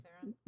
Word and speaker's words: Theron: Theron.